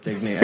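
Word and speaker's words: Digne: So I, yeah, it's definitely pronounced Digny. I think everyone else has been incorrect, so I'm going to go with that.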